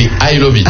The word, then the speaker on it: iloveat: C'est I Love It. Alors, ça se prononce en anglais, mais c'est écrit I-L-O-V-E-A-T. Donc, I-L-O-V-E-A-T.